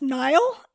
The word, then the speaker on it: Niall: The other ones you were looking for were, I assume it's pronounced Niall, N-I-A-L-L, Niall Horan, Liam Payne, Louis Tomlinson, and Zayn Malik.